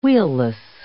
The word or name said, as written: wheelless